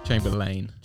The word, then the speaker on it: Chamberlayne: Chamberlayne.